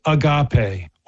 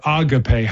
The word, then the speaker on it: agape: And then the third kind is agape, or agape, however you want to pronounce it.